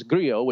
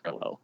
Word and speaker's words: Grillo: There's Griot, which is the Spanish pronunciation. He wants Americans not to try. He said, just say Grillo. And then I think there's an Argentinian pronouncer that's Grillo. I don't know. So we'll go with Griot.